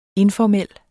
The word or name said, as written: informel